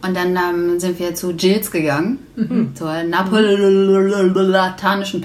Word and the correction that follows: neapolitanische: Pizza. Mhm. Könnt ihr das aussprechen? – Ich kann es nicht aussprechen. Neapolitanische Pizza. – Oh, ich wollte Napoleon sagen. – Ja, ich irgendwie auch immer. – Das kommt ja aus Neapel.